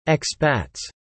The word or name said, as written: expats